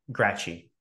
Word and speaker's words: Graci: Graci.